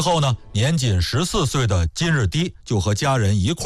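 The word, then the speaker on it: 金日磾: 武帝看上的第二位顾命大臣呢是匈奴籍的，叫金日低。在这儿啊我们稍微再多说一句啊，就是我看有的纪录片里边呢把这个金日低读成是金日密，我查了相关的这个资料没找到这个密字的发音的出处，所以咱们还是说成是金日低吧。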